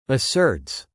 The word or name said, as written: asserts